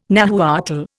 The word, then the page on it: Nahuatl: Indian